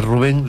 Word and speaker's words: Rubén: Rubén Rada. No sé por qué le ponen a Rubén, le ponen un acento en la U. Rubén no es Rubén.